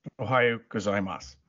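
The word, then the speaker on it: Ohayou gozaimasu (おはようございます): Yoroki Nagimas. I just butchered that, but I was saying hello, welcome. I'm welcome to be here. – Ohayou gozaimasu.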